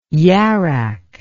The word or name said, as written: yarak